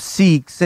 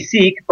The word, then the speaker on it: Sikh: Yeah, so anglicized it, they say Sikh, but in terms of from the way we pronounce it, it's Sikh. Yeah, S-I-K-H.